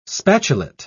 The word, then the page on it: spatulate: British English